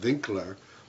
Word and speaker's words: Winkler: Today we're going to talk about a title called, it's spelled Winkler with a W, but probably in those times it was pronounced Winkler, German.